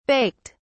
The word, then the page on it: baked: British English